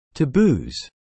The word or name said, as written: taboos